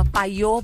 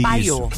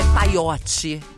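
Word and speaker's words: Payot: É arroba paiô Brasil. Só que, ó, é P-A-Y-O-T e Brasil. – Isso. – Paiô. É paiote Brasil. – Se escreve paiote com Y, né? Mas a pronúncia é paiô Brasil.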